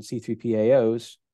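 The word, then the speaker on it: C3PAO: But now that's not good enough, and we're actually heading down the path of CMMC and C3PAOs. Did I say that right? I think I did. – Yes. – It's that Star Wars thing and messes me up.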